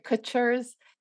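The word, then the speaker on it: Kutsher's: So when I watched that documentary about Kutcher's, or am I saying it right? Kutcher's. Sorry. – Yeah, you're saying it. You got okay. it. You got it.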